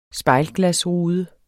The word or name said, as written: spejlglas